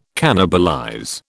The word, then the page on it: cannibalize: British English